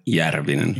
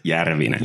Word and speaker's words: Järvinen: Järvinen. Oh, yeah, from my native Tampere, it's Järvinen.